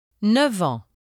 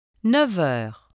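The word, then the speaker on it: neuf: When 9 precedes the words en and heure, the final consonant is pronounced v. Listen and repeat. – 9 ans, 9 heures.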